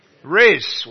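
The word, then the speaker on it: rizz: It rhymes with fizz. Riz.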